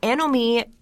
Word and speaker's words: anomie: And the concept is called anomie, and that's spelled A-N-O-M-I-E. It's a French term, so I'm pronouncing it that way. It sounds a lot better in French, anomie, just rolls off the tongue.